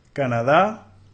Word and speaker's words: Canada: Canada, Canada, Canada. Nota el estrés en la primera sílaba, Canada, Canada.